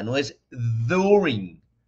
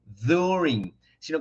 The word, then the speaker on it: during: Lo primero es que la pronunciación de during no es con la D latina, no es during. During, sino que sería más como una combinación entre la Y y la Y de yuca o la Y y la D como un during, during.